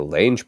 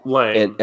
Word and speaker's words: Lange: You know, I was trying to figure out where I'd seen Jessica Lange before. Is that how you pronounce her last name? – Lange. And then, I think it's just Lang.